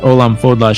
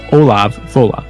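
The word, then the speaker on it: Ollamh Fodhla: And by the way, the compilers there say that Olam Fodla should actually be pronounced Olav Fola.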